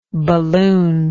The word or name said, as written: balloonlike